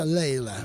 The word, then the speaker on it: Layla: Se escribe Layla, pero se pronuncia Layla.